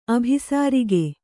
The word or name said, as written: ಅಭಿಸಾರಿಕೆ